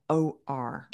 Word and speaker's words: Omlor: Oh no, OOR. So it's JEANNE OMLOR.